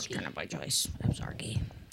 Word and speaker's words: Absarokee: I can never pronounce this right, Evzarki. Is that where they're playing? Or no, they're playing. – They're playing Absarokee.